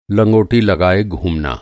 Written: लंगोटी

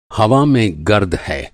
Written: गर्द

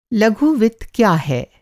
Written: लघु वित्त

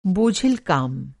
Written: बोझिल

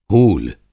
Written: हूल

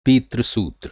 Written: पितृसूत्र

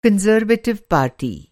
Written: कंज़र्वेटिव पार्टी

